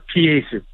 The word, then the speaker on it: Peyasu: pronounced Peyasu.